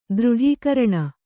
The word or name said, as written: ದೃಢೀಕರಣ